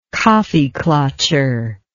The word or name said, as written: kaffeeklatscher